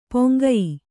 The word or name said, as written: ಪೊಂಗಯಿ